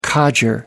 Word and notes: codger: American English

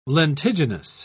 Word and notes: lentiginous: British English

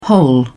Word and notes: hole: British English

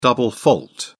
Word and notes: double fault: British English

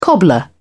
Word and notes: cobbler: British English

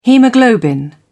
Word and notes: haemoglobin: British English

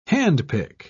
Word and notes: handpick: British English

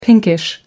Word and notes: pinkish: American English

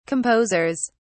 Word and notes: composers: British English

